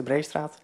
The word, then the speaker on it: Breestraat: We gaan het komende jaar, en ik denk volgens mij ook volgend jaar, gaan we een onderzoek doen naar de Breestraat in Marknesse. Zeg ik dat goed? Dat is de Breestraat.